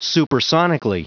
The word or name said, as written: supersonically